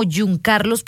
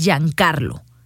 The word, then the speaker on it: Giancarlo: Mire, me lo pusieron como Giuncarlos, pero yo creo que es Alexis Giancarlo García Reyes, de 23 años de edad.